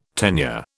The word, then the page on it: tenure: British English